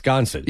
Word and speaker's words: Sconset: Ah, that's pronounced Sconset. – Yeah, Sias Sconset is the, but they call it Sconset.